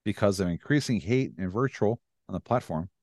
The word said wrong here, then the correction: vitriol: Is that how you say it? Virtual? – Vitriol. – Vitriol. Thank you.